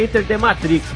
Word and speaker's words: Enter the Matrix: É Enter the Matrix, maluco.